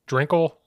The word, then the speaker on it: Drinkle: Drinkle? Is that how you say his name? – Yes, that sounds right.